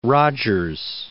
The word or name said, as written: Rodgers